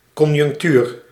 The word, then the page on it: conjunctuur: /kɔn.ʒʏnk.ty:ɾ/